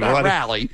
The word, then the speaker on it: Raleigh: It's Raleigh. It's like Greg Raleigh from Journey. – Yeah. – That's how you pronounce it. – Thank you. – Raleigh.